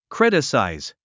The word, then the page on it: criticize: krítəsàiz; クリタサァイズ